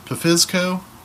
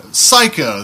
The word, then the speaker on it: Psycho: Then there's a little bit of a turning point in horror cinema where a film by the name of, is it Pfizco? Psycho, that's how you pronounce it.